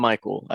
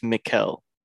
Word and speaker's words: Mikkel: It, or just I don't want to say Michael. It's not Michael. I think it's Mikkel.